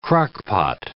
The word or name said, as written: Crock-Pot